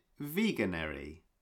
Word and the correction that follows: Veganuary: Yeah, did I? – Is that how you say it? – I think so. Sounds okay. I always, because nobody says it out loud. I only ever see it on Instagram. I thought it was called veganuary.